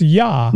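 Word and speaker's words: Jaa: So you got to meet, you know, Tony Yaw, and it's pronounced Yaw. – Yeah. – It's a silent J.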